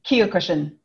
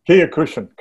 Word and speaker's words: Kyokushin: and I'm going to say this wrong, Kyokushin karate, is that how you say it? Kyokushin. Kyokushin, karate as well.